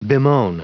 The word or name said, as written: bemoan